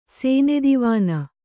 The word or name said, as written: ಸೇನೆದಿವಾನ